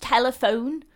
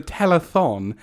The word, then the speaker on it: telethon: Yeah, it's pronounced telephone. God, you're so fucking thick. I can't believe I'm wasting my time with this. God, old people don't fucking know anything, do they? – Charmaine, it's a telethon.